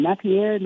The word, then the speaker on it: Napier: Anthony Richardson had had how do you how do you say his name? Napier, not Pierre.